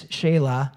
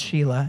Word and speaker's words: Shelah: By the way, the pronunciation is Shelah. And no man wants to be called Sheila, even though it looks like Sheila. It's Shelah.